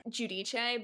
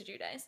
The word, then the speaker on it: Giudice: So, Real Housewives of New Jersey, Teresa Giudice. – Is that how you say it? – Well, that's how they say it. – Oh, okay, okay. – As, like, Jersey Italians. – Okay. – Giudice, but...